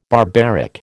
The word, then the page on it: barbaric: American English